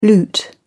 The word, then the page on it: loot: British English